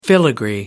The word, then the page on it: filigree: American English